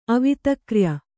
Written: ಅವಿತಕ್ರ್ಯ

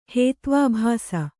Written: ಹೇತ್ವಾಭಾಸ

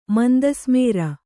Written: ಮಂದಸ್ಮೇರ

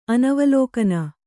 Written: ಅನವಲೋಕನ

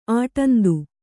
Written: ಆಟಂದು

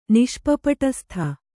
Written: ನಿಷ್ಪಪಟಸ್ಥ

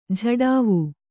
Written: ಝಡಾವು

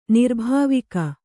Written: ನಿರ್ಭಾವಿಕ

